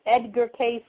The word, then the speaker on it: Edgar Cayce: You get little readings when you go over there to the Edgar Cayce place. And by the way, it's Edgar Cayce, and it's spelled Cayce.